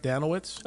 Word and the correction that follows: Danilovich: Uh, Danilovich is how he Danilovich. says it with the V sound.